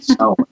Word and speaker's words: Samhain: Celtic tradition, uh, it's called this period of time is called Samhain, in this weird Gaelic spelling, S A M H A I N. Now you go figure out how they call it Samhain or why it's pronounced that way. I have no idea, but that's the pronunciation, Samhain.